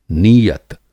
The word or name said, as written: नीयत